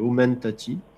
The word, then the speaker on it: Omentati: Pas comment on prononce exactement. Je vais prendre tendance à mettre un augment, mais non, c'est Omentati.